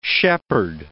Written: shepherd

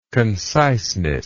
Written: conciseness

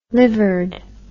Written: livered

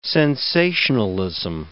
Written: sensationalism

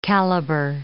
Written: calibre

